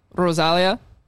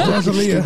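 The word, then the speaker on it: Rosalía: Bisco Chito, Rosalia. Did I say that right, boys? – Yeah. No, you didn't. – just Rosalia.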